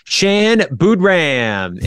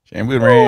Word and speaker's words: Shan Boodram: It is Shan Boodram, and yes, it rhymes. – Shan Boodram.